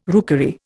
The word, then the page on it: rookery: Indian